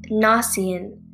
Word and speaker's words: Gnossienne: The next one is Gnossian, but it's spelled Gnossienne, much different from how it sounds.